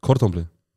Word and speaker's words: Cordon Bleu: Okay, dann sag Gordon Bleu und mich spricht aus. Cordon Bleu. – Cordon Bleu. – Gordon Bleu. – Der blaue Faden. – Ja, Gordon Bleu. – Ja, jetzt hat es nicht schlecht ausgesprochen. – Gordon Bleu? – Ja. – Du? – Cordon Bleu. – Ah, okay, das Ding ist Cordon Bleu. Cordon Bleu, okay.